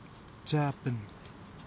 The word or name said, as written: tasiapen